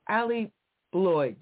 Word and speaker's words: Allie Bloyd: Welcome for the first time to the show, Allie Bloyd.